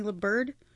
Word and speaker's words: Liburd: Melanie LaBird is who plays her in this. – I think she was on what was it called, The Parkers? What did you say her name was? Melanie LaBird.